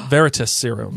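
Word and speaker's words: Veritaserum: How do I say that word? – Veritas serum. – Veritas serum. Thank you.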